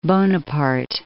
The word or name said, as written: Napoleon Bonaparte